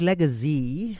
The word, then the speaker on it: Legazy: So does Legacy, am I saying it right? – Correct, perfect.